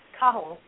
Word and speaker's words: Cahill: And then Cahill, which is actually pronounced Cahill, is like Smith over there.